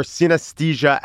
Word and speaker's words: synesthesia: How do you pronounce synesthesia? – Synesthesia. – Good job.